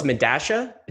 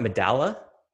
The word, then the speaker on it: Medalla: Wait, so it's Medasha? It's not Medalla? – It's not Medalla. It's spelled Medalla. It's pronounced Medasha. – Wow, what language is that? – I think it's Spanish. – Okay. – I think there was some game of telephone where we lost the original spelling or original pronunciation. I don't know, but Mariano Conti pronounced it Medasha, and so I trust.